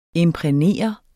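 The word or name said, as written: IMPRÆGNERE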